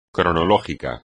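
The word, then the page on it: cronológico: español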